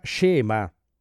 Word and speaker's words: schema: Si scrive schema, ma si pronuncia scema.